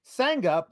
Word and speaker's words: Sangha: Um, Mark Losper says, fun fact Sangha, pronounced like it rhymes with banger.